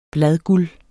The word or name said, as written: bladguld